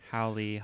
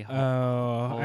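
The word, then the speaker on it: Hawley: I'm going to ask that insurrectionist Republican member of the House, that Mr. Howley, however you say his name. – Howley. – Howley.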